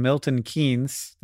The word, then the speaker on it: Milton Keynes: Yeah, so, it's weird that the franchise currently applying its trade in Milton Keynes. Did I say that right? – You did.